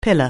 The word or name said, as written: pillar